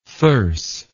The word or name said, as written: thyrse